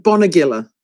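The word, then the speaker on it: Bonegilla: Bonegiller. – Bonegiller. – But you're not actually wrong, because there are actually three established ways to say that. Bonegiller, Bonegiller and Bonegiller.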